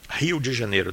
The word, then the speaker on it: Rio de Janeiro: Rio de Janeiro, that's how we pronounce it.